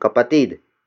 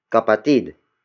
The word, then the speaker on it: kapatid: The next word is kapatid. Kapatid. Kapatid. Kapatid is spelled as K-A-P-A-T-I-D.